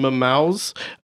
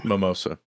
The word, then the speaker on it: Momoa: when Jason Momoa's, I don't know how to say his name. – Mimosa.